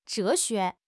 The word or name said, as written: zhe2 xue2